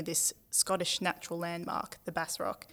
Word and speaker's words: Bass Rock: I've been saying it Bass Rock for so long, but I'm listening to the audiobook now and it's apparently pronounced Bass Rock. My bad.